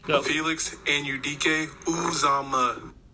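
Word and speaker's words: Uzomah: Emphasis on the first syllable. Uzama. – Uzama. And by the way, I've never heard it that way, by the way. – Just saying that to clarify the emphasis instead of seeking for you to pronounce it quite that loudly and bluntly. – Well, I just want you to know it's ooze.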